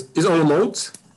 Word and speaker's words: Olomouc: Yeah, it's Olomouc.